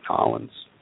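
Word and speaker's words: New Orleans: So to say it right, Nolens.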